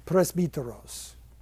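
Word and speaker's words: Presbyteros (πρεσβύτερος): Presbyteros. That's how Greeks today pronounce it, which I guarantee you is not how they pronounced it then. But at least a Greek today would know what I'm saying.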